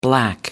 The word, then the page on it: black: American English